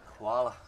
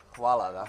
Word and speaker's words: hvala: How do you say thank you in Croatian? – Hvala. – Hvala? – Hvala, da. – Hvala? – Yes.